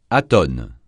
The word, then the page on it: atone: francais